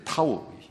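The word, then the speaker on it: 타우 (תו): 근데 이 표라는 단어가 이 히브리어로 보니까 이 영어 발음으로는 T인데요. 타우. 히브리어로는 알파벳이 타우예요. 타우가 이게 T 비슷합니다. 완벽하게 T는 아니고 타우예요.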